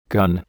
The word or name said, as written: gun